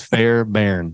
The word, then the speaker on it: Fairbairn: It's a tricky name because it's Fairbairn. – That's, you sure that's how it's pronounced? – Yep.